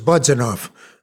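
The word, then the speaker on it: Budzanów: Your family originally came from the town of Budzanov, B-O-D-Z-A-N-O-W. Budzanov, some people pronounce it, but my father used to, in Yiddish, it was Budzanov.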